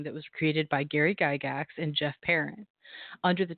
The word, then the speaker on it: Gygax: So it was first designed by the legendary Gary Gygax. Did I say that right, Zach? – Yes. – Gygax? – That is absolutely correct.